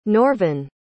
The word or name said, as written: norvyn